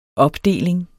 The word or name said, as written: opdeling